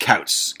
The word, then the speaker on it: Koutz: it's pronounced Kautz, kind of like cowl, but Kautz.